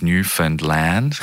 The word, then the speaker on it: Newfoundland: And I got to remind myself, it's Newfoundland, rhymes with understand Newfoundland, understand Newfoundland.